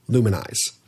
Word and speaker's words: Lumenize: It's made by Arcadia, and it's spelled L-U-M-E-N-I-Z-E, Luminize.